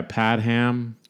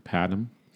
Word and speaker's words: Padgham: Yeah, Padham, P-A-D-G-H-A-M, Padham, who he also did like the Phil Collins solo records. He did Stings. – Did he really? That makes sense. – Yeah, so Hugh Padham, his production credits are Phil Collins.